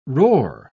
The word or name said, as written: roar